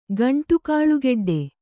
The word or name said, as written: ಗಂಟುಕಾಳುಗೆಡ್ಡೆ